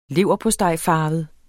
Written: leverpostejfarvet

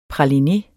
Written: praline

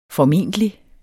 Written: formentlig

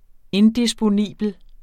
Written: indisponibel

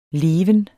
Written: leven

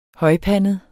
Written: højpandet

